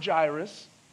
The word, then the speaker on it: Jairus: It looks like it's spelled Jairus, it's hard for me to remember. It's pronounced Jairus.